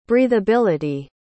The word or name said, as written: breathability